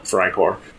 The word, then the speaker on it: Freikorps: But Freikorps, if you're new to the game, I will always correct you if you don't call it Freikorps, Kyle.